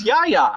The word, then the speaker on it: Yahya: I think the guy's name is Yahya. – Is it really? – I mean, that's what it said. That's how I... How else could you pronounce Y-A-H-Y-A? Got to be Yahya Abdul Mateen.